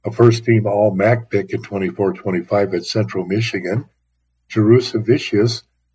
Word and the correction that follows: Jaruskevicius: okay, it's pronounced Ugnius Jaruskevicius,